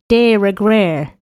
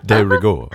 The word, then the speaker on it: de rigueur: Just be glad you don't live on a block where extensive holiday decorations are de rigueur. How do you say that? – De rigueur. – De rigueur. Thank you.